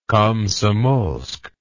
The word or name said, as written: Komsomolsk